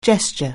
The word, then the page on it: gesture: anglais